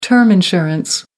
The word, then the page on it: term insurance: American English